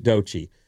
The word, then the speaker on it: Doechii: Her name is spelled crazy. – Yeah, it's Doechii, Doechii. – They pronounced it Doechii.